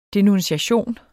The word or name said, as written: denuntiation